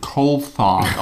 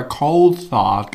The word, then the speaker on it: Coulthard: So wie damals, als für zwei Formel-1-Rennen plötzlich Jochen Maas und Heiko Wasser gesagt haben, dass sie jetzt rausgefunden haben, dass David Coulthard gerne David Coulthard ausgesprochen werden möchte. Und dann haben sie das zwei Rennen lang immer Coulthard gesagt und dann haben sie wieder Coulthard gesagt, weil sie wahrscheinlich korrigiert wurden, weil es einfach Quatsch war.